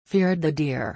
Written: feared the deer

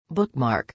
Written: bookmark

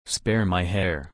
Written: spare my hare

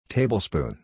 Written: tablespoon

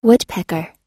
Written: woodpecker

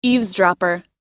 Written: eavesdropper

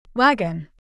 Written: wagon

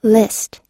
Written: list

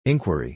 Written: inquiry

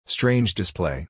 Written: strange display

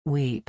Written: weep

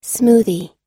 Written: smoothie

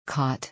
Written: caught

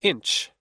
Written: inch

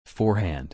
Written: forehand

